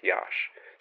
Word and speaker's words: Iași: We're going to be doing Iași in Romania, and it's spelled I-A-S-I. But as far as I can tell from looking up things, it's pronounced Iași.